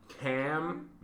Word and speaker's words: Camilizers: No, it's three syllables. Camilizers.